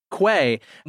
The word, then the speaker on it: Quay: I see that the article that you have in the blog was actually written by, how do you say his name? Key Morgan? – Quay.